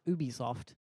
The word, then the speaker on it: Ubisoft: Well, I believe it's pronounced Ubisoft. – Yeah. – But everyone calls it Ubisoft.